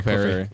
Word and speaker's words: Clefairy: Clefairy? – Yeah, Clefairy.